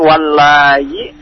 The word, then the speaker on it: وَاللَّائِي: Jadi bacanya panjang. – Jadi walai gitu. – Jadi la-nya panjang juga. La-nya 4 sampai 5 ya. Karena dia mad wajib muttasil.